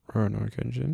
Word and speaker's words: Rurouni Kenshin: Rurouni Kenshin.